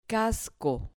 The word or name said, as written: casco